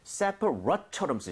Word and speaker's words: separate: Separate처럼 쓰셔야 돼요. 그래서 rate이 아니라 rut.